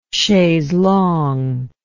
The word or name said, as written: chaise longue